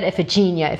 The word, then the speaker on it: Iphigenia: They said Iphigenia, Iphigenia. So I was like, all right, fine.